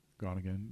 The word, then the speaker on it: Groningen: He lived from 1714 to 1799, and he was educated both at the University of Edinburgh and at Groningen. Is that how you say it? – Groningen, yeah.